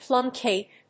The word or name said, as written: plum cake